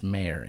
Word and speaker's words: Oscar Mayer: M-A-Y-E-R. – It's Oscar Mayer. – No, it's not. – What? – It's pronounced Mayer, but it's spelled M-A-Y-E-R. Just think the song. – That's ridiculous. – M-A-Y-E-R, like John Mayer.